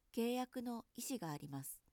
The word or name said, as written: いし